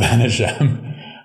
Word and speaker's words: Vaneigem: Raoul Vanat. Yes, absolutely. So Raoul Vaneigem. Um, that was not me retaking a name I mispronounced.